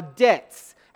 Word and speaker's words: debt: Because we don't like saying debts, and half of us pronounce debt with the B instead of the silent B.